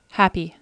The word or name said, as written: happy